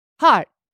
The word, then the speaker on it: heart: Art. Heart. Sorry. – Heart. – I'm from art. Across the river where we don't say our H's that pronounced. – Oh, yes, you're right. – Like, how are you? – No, yes, I'm born of a more nobler position, so I pronounce my H's.